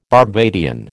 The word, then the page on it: Barbadian: American English